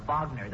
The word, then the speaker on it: Wagner: The record says it's by Richard Wagner. – Yes, Dave. And naturally, I know that particular piece is by Wagner. That's pronounced Wagner, son.